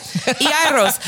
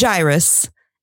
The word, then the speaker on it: Jairus: Well, it's a Greek it word. Like that. – Iaros. So I would have said Jairus, and you said Jairus. – I listened to four different pronunciations. – It's like Iaros.